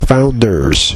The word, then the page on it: founders: American English